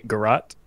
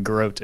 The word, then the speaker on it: Garrote: Apparently, Garot is the primary pronunciation. Garot is also apparently acceptable.